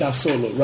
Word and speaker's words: Asolo: And that's called Asolo? – It's Asolo, right. – How do you spell it? – A-S-O-L-O.